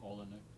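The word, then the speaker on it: Oleinik: How do you say his name? – Olenek. – Is it Vasily? – Alexei. – That's right. Alexei Olenek.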